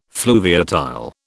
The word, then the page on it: fluviatile: British English